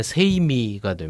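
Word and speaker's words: 세이미가 (舎密): 이게 이제 당시 일본어 발음으로 읽으면 세이미가 됩니다.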